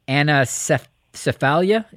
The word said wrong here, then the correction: anencephaly: is it close? – Yeah, you're in the ballpark. It's anencephaly.